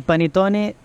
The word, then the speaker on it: panettone: So here we have the il panettone di Milano.